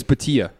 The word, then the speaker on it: Bhatia: Apparently it's Bhatia.